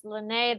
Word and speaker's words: Linné: I want to talk all things Linné. That's how it's pronounced, correct? – Correct. Yeah, it's Linné, the last name um, of Carl von Linné.